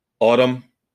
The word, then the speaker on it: Atum: Autumn, ATUM, but I believe Billy Corgan has already come out and said you pronounce it like the season autumn.